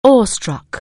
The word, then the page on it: awe-struck: anglais